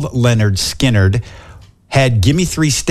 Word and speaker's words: Lynyrd Skynyrd: Lynyrd Skynyrd, they made an album and it's pronounced Lynyrd Skynyrd.